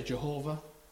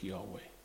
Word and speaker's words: Yahweh: Some say Jehovah, but I'm led to believe that the correct pronunciation is Yahweh, meaning I am what I am.